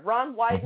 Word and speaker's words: Wyden: Ron Wyden mm-hmm. rhymes with Joe Biden, and he's from from the Northeast.